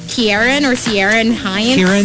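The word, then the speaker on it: Ciarán Hinds: Kieran Hines.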